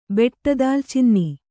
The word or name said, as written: ಬೆಟ್ಟದಾಲ್ಚಿನ್ನಿ